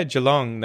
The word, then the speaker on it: Geelong: And that's fairly near Geelong. Am I pronouncing it right? – Geelong? Yeah. Geelong. That's 25 minutes away.